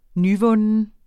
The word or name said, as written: nyvunden